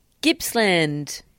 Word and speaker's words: Gippsland: What was the place that I learned how to pronounce? – Gippsland. – Gippsland. There we go.